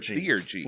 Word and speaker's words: Theurgy: So you say the name Theogy. – Theurgy. Like theological, yeah, theologians, theurgy.